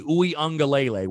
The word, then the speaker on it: Uiagalelei: Uiagalelei, which, by the way, kudos to me and a pat on my back that I pronounce his name correctly.